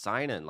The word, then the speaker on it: Synan: I'll go, it's Synan, like sign in your name.